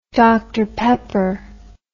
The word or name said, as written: dr pepper